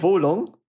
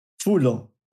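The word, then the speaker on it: Wo Long: Du bist für Wolong? Wulong. Wulong. Okay. – Ein weiches W und O. Wu. Ja. Wu, wie der Wutong, Wutong-Clan, ja.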